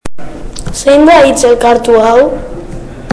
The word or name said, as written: hitz elkartuak